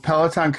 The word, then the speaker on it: Peloton: So Peloton Coaching, a lot of people like sort of link it to those exercise bikes, Peloton cycles.